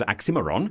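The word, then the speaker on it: Oxxxymiron: And in fact, in this particular song, Miron, whose stage name is Oxymiron, if you have a Moscow pronunciation, it's Oxymiron.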